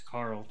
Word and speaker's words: Quarl: I can't tell if I'm supposed to pronounce that Carl. Carl. – It's Carl. – Okay, I'm going to say Quarrel because I like that better.